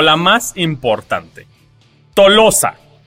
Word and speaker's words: Tolosa: En francés es la ciudad de Toulouse. – Toulouse, ajá. – Ajá. Pero en español se pronuncia Tolosa.